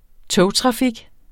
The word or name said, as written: togtrafik